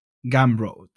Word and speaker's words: Gumroad: Y pocos o más después me estoy mirando otro que es Gumroad, que son americanos, es muy parecido a Paddle. – ¿Cómo has pronunciado? Es que mi inglés es pésimo. – Gumroad.